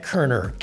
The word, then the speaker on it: Koerner: Brad Kerner, K-O-E-R-N-E-R.